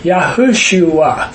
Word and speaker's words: Yahushua: We say Jesus when his name is actually Yahushua, four syllables.